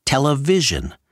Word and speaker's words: television: Note that Sarnoff pronounces television as television, accent on the vision, because that was the remarkable breakthrough.